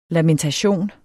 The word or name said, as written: LAMENTATIONER